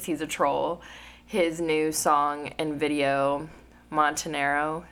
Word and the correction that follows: Montero: I thought it was Montero because Montero. – Yeah, I think his real name is Montero. Oh.